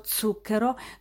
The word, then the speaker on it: zucchero: perché se volevo lo zucchero dovevo pronunciarlo con la Z aspra e sorda e non dolce o sonora e poi,